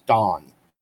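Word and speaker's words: Daan: His name is Don. So it's D-A-A-N. So it sounds like Don, but it's spelled Dan.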